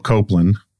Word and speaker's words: Coupland: I was recently rereading this piece, this academic piece by this guy, Philip Copeland. I think that's how you pronounce his name. It's C-O-U-P-L-A-N-D, called H.G.